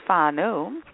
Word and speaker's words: Farnow: His name is Dan Fano, although he spells his name F-A-R-N-O-W, but it's pronounced Fano, which is good. And not far now, okay?